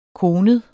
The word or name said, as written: konet